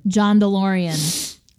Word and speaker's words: DeLorean: And well, you said it wrong. – Oh, here, do it. – Say it. – John DeLorean. Thank you.